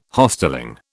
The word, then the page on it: Hostelling: British English